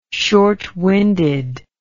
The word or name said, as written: shortwinded